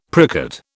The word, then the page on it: pricket: British English